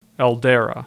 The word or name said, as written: El Dara